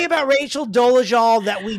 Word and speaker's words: Dolezal: We are speaking, of course, of Rachel Ann Dolezal, because that is how it's pronounced. It is Dolezal.